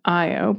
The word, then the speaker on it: Io: And her fluffy black cat, Io, as in Jupiter Moon Io, pronounced Io. Well done, Dana.